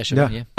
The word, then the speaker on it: Chabon: I'm interested in the involvement of Michael Chabon. I hope I'm saying his name correctly. – Yeah, Chabon, yeah.